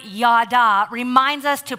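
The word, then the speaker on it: yada (ידה): But before we get to that, I want to point out that the Hebrew word used here for praise is pronounced yada. Say it with me, yada.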